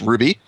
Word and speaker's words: RWBY: and anime fans may be aware of the series Ruby, RWBY, for those who maybe don't realize that's how it's pronounced.